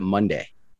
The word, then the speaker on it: Monda: Am I pronouncing that correctly, or is it Monday? – It is Monda.